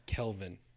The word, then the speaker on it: Kelvin: Calvin is so much easier to say. – No, Kelvin. – Calvin is easier to say, though. – Like Fahrenheit, Celsius, Kelvin.